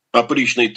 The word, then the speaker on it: опричный: И все это рухнет, и уже через э, какие-то пять лет грянет опричный террор. Я сознательно с ударением на первом слое произношу, потому что это перевод ну да. латинского ужас.